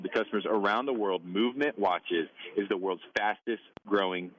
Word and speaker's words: MVMT: are movement watches. When you see it in ads or on Instagram or whatever, it's going to be spelled MVMT, but it's pronounced movement, was founded on the belief that style shouldn't break the bank.